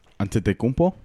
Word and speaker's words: Antetokounmpo: Antetokounmpo.